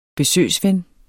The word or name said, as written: besøgsven